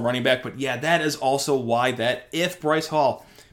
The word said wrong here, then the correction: Breece Hall: Breece Hall, excuse me, Breece or Bryce Hall, why, What do I keep saying? Yeah, Breece Hall.